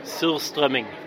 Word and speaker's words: Surströmming: Surströmming. – All right, here we go. Surströmming. All right, let's put this herring that's been fermented for how long? – About 6 months or so. – Surströmming.